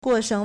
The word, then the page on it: 过剩: 過剩; guòshèng